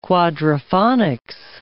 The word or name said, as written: quadriphonics